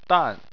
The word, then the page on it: dan: 4